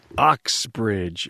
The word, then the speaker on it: Uxbridge: A warm welcome to Advanced Automotive Performance in Uxbridge, Massachusetts. Did I say that right? – Uxbridge.